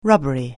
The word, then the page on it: rubbery: anglais